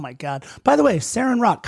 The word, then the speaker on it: Seren Roc: Seren Roch. And it's spelled S-E-R-E-N-R-O-C. Seren Roch, baby.